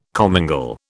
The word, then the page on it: commingle: British English